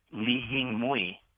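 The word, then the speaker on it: Li Hing Mui: But you'd need to know that it's lihimui, not li, I think you guys mispronounced it before. First word is L-E-E or L-I, mm-hmm. – Hing, H-I-N-G, and then the third word is M-U-I.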